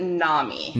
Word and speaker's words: NAMI: So we pronounce it NAMI. – NAMI. Okay. – We've been saying it wrong.